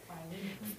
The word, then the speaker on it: Philemon: I always have a hard time saying this in English because Philemon, is that right? Did I say it right? – Philemon. – The book of Philemon. Okay. In Spanish, it's Filemon. Isn't that fun? Philemon. So I always look at that and I want to say it the wrong way. Philemon. I don't even think I knew how to say it in English. I probably learned it in Spanish first.